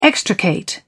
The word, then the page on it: extricate: British English